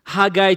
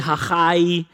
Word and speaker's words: Haggai: Haggai chapter 1, I don't know your pronunciation. Today's Heritage Day. You can say Haggai, Haggai for the Americans, Haggai.